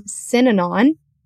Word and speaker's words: Synanon: And anyone listening, look up Synanon, and it's spelled S-Y-N-A-N-O-N, Synanon.